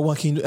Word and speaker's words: Joaquin: Kind of, to me, he kind of looks like, however you say his name, Jack and Phoenix. – Oh, Joaquin.